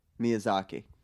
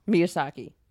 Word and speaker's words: Miyazaki: Miyazaki. – Miyazaki.